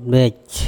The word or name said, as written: bEc